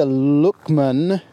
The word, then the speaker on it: Loughman: In 2020, Maeve starred in the supernatural comedy film Extraordinary from Irish directors Enda Luckman. Now, I did Google how to pronounce that name, Luchman, L-O-U-G-H-man, Enda Luckman.